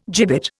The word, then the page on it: gibbet: Indian